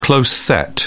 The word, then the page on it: close-set: British English